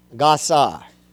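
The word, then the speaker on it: gazar (גזר): The word for decree in the Hebrew is the Hebrew word gazar. Brother Dean, that's G-A-Z-A-R, but it's pronounced G-A-W, gazar.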